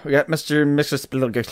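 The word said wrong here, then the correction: Mxyzptlk: next week. I said that right? – Mixer Spittlick. – Let's see how this show pronounces it, then. I think he did it. I think he did Mixer Spittlick.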